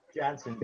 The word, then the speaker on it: Janzen: So Courtney Janzen, is that how you pronounce it, or is Jansen? It's Janzen, right? – Janzen, J-A-N-Z-E-N, yes, sir.